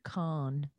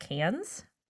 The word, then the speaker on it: Cannes: He's like, maybe it'll go to Sundance, maybe even Cannes. – It's actually pronounced Cannes.